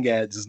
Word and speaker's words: Gaddis: eu tenho uma raiva do Paulo Guedes pelo seguinte, porque um dos meus autores favoritos é o norte-americano William Guedes, né, G-A-D-D-I-S, né, que se pronuncia como uhum. Guedes,